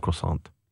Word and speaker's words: croissant: And the T should be silent, right? – Did I say it? – You said croissant. – Yeah, okay. The T is silent unless it's followed by an E.